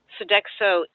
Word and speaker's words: Sodexo: So one of the things I wanted to bring up was you also did a big investigation of Sodexo. I think that's how you pronounce their name, the big food company. – Yes. Yeah. – Tell us a little bit about that. – Sodexo is one of the three worldwide food service providers.